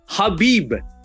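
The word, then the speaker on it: Khabib: Habib. I said it right. And I said it with the correct pronunciation, too.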